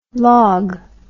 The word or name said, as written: logue